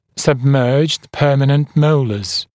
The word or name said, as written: submerged permanent molars